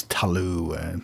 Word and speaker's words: Cthulhu: I had a friend years back who always referred to him as Tulu. I think Lovecraft, you know, in his letters kind of talked much more about it being a guttural or, you know, grating sound, sort of Cthulhu or something like you're clearing your throat.